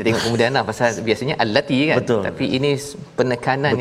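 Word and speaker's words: allati (اللاتي): cara bacanya apa ustaz ya uh, al dua harakat dua harakat ya ah, allati oh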